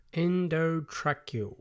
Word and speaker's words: endotracheal: It's called an endotracheal tube. That's how you pronounce it. Endotracheal.